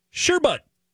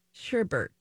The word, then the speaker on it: sherbet: Your second word here, round number two, is sherbet. – Sherbert.